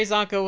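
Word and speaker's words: Csonka: Is it Larry Kasanka? – Larry Zonka. – Larry Zonka. Yeah, Larry Zonka passed away yesterday.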